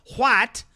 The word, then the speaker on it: white: If that was a white woman, and I pronounce the H in white, white, if that was a white woman, we would have wall to wall coverage on ABC, CNN, MSNBC, CBS.